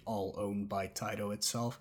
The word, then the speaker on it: Taito: Yeah, I'm pretty sure it's pronounced Taito. Like, so, like very fast, like Taito.